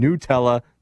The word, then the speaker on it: Nutella: If the company says you pronounce their name Nutella, that's how it's pronounced.